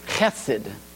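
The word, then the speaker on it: Chesed (חסד): is really from the Hebrew, Bet Chesda. Chesed. Those of you that have heard, had people pronounce Hebrew, Chesed.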